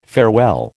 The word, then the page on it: farewell: American English